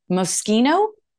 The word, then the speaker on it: Moschino: It's Moschino.